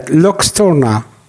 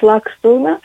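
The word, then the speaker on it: Luxturna: בעברית האמריקאית, לוקסטורנה. – כן, כן. – אוקיי. – L-U-X, אה, אז אוקיי, אז בעברית אמריקאית לוקסטורנה, באנגלית אמריקאית לקסטורנה. לקסטורנה.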